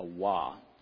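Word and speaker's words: Waw: If you a Hebrew today, they would say Vav in the Hebrew today. The V is how they would pronounce it. But in the biblical times, it was a Wah.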